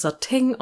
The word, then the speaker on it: Satin: Ja, aber wie kann man das denn in Deutschland akzeptieren, dass man das Sateng ausspricht?